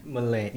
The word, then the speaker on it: Malene: Malene? Or is it Malini? It looks like Malene. – Malene, yeah. – Malene.